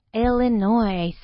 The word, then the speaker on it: Illinois: In English, the S is silent, okay? So we say Illinois.